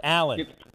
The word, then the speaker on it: Allen: I said Island. – Are you making Josh fun of my Allen. Accent again? Josh Allen. What's the matter with you? Pronounce the name Allen. It's not a hard one.